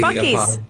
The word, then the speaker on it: Buc-ee's: Bussy, how do you pronounce B-U-C? – Buc-ee's. That's Buc-ee's.